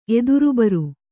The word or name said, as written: ಎದುರುಬರು